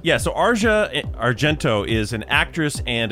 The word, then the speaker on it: Asia: A lot of people call her Asia Argento. – Right, The proper way to say her name is Asia, but a lot of people know her, I think, as Asia Argento.